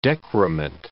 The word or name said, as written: decrement